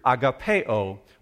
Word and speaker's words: agape: So that word love is the word agapeo. Or perhaps if you've grown up in church, you've heard it pronounced agape.